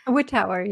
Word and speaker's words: Wittauer: Is that how I say your name? – Wittauer, yeah.